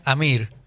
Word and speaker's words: Amr: Amr Mokhtar, que lo trajo de Egipto. Amr Mokhtar, el asistente de Diego. – No, se pronuncia Amir. Pero le falta la I.